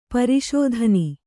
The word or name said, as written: ಪುರುಷತನ